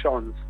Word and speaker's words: Jones: Como quieras, pero a ver, es de ascendencia galesa de la colonia de la Patagonia, se le suele decir Jones.